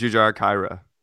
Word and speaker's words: Jujhar Khaira: How do you say it? I think I think it's I thought it was Jujhar Khaira. Khaira, there you go.